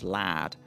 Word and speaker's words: lard: So one of the other ingredients for bread she talks about is lard, which she pronounces as lad, and that's still very much the pronunciation right. – in large parts of Yorkshire and the north of England for words like car park.